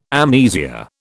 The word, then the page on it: amnesia: British English